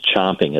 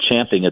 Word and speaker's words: champing: If you want to look at champing at the bit versus chomping at the bit, you'll see, you know, you can just plug in those two phrases and you'll see that champing at the bit in books, which is the correct way to say it, is, it's a horsey term, you know, far and away in books is more prevalent.